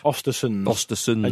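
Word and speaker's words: Östersund: Frank de Boer, Jaap Stam, Chris Coleman, Swedish side Osterund's manager... Graham Potter. – Ostersons. And you were a host of On the Continent, so you knew the correct way to pronounce that.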